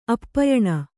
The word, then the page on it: ಆಪ್ಯಾನ: āpyāna